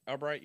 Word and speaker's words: Albrecht: How do you pronounce it? Sergeant Albrecht? – Albright, yeah.